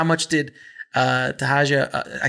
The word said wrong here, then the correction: Taraji: I can never pronounce her name, Tahaja. Taraji. Yeah.